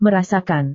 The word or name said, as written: merasakan